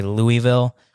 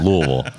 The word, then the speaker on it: Louisville: Like, that's what you got to do. Louisville.